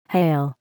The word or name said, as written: hail